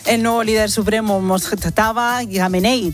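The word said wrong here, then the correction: Mojtaba Khamenei: dice... ¿Eh? Mojtaba Yamenei. No. Me dijiste que era así, ¿no? – Sí, pero lo he dicho tan mal.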